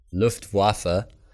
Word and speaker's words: Luftwaffe: No, Luftwaffe. Luftwaffe is the German pronunciation, the aerial warfare branch of the combined German Wehrmacht military forces during World War II.